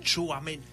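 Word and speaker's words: Tchouaméni: Destaca, por supuesto, Kylian Mbappé, está Camavinga, está eh, Toumaney, Toumeni, o cómo se dice este? Chuameni. Chuameni, ok.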